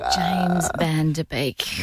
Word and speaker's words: Van Der Beek: Right person and then pronounce the name wrong. – James Van Der Beek.